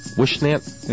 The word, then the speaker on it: Whisnant: Ray Wisnant. W-H-I-S-N-A-N-T. – What'd you pronounce it? Wishnant? – Yeah.